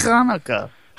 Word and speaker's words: Hanukkah: But you got to pronounce it right. It's Hanukkah. – You got to get the phlegm going.